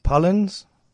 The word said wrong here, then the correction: Pollan: Is that correct? – Michael Pollan, Pollan, all right.